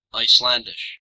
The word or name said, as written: Icelandish